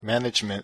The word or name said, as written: management